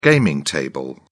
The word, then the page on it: gaming table: British English